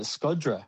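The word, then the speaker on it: Shkodra: Yeah, and we went to Skoda, which they actually pronounce Scod. They pronounce it Skodra.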